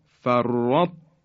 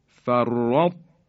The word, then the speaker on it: فرطتم: برای اینکه تای دسته دار که ساکنه قوی تر از مدغم فی یعنی تی دو نقطه است. به این خاطر بایستی دقت کنیم و اینطور تلفظ کنیم فرطو، فرطو، اثری از درشتی حرف تا باقی مونده. یا کلمه احطو، احطو، یا فرطتم، فرطتم، این حرف تا دسته داره که ساکنه بعد حرف تی دو نقطه اومده، به اینها میگیم ادغام تام.